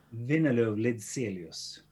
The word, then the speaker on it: Windeløv-Lidzélius: Windeløv-Lidzélius.